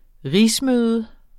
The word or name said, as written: rigsmøde